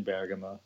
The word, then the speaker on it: Bergamo: Oh, Bergamo. Yeah, Bergamo. Yeah. I've been there. I used to do a lot of road races on this bike and everything. And one of them started in Bergamo.